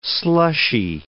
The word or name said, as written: slushy